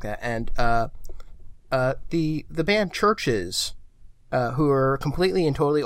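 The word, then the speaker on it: CHVRCHES: I think you're thinking of Churches. – it's pronounced Churches. I'm almost certain.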